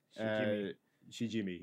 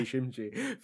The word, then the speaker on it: Shijimi: like on the undercover alias of everyday Shijimi. Yes, so I pronounced it wrong the whole time. I thought it was everyday Shimji for the longest, but I only realized today it's pronounced that way.